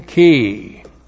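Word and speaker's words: ki (כִּי): We start out with the conjunction ki. K long I and ki is a causal conjunction and it is correctly translated because.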